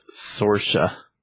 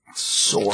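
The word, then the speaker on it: Saoirse: So that is pronounced Sorsha. – Sorsha. – Just so you know. I saw that on Oprah.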